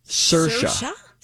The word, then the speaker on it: Saoirse: Pronounce her first name. – Saoirse? – Close. – Sersha. – Sersha. Sersha like inertia. – Okay. – But it's spelled Saoirse. Oh. Irish. Saoirse.